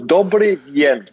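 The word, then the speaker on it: Dobrý den: Dobri Den ist, oder, oder wie wird das richtig ausgesprochen? Genau, ne? du, bist, du bist schon ganz gut. Dobre Yen.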